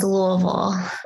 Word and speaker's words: Louisville: So for starters here, what is the correct pronunciation of the town that you live in? – I say it as Louisville.